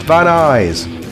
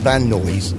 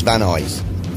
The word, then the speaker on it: Van Nuys: Van Nuys in California is pronounced Van Eyes. There you go. I can't remember us talking about that. – Oh, well, we did, because I said, is it pronounced Van Noise? Ah. Van Nuys. I had real problems, but it's Van Eyes.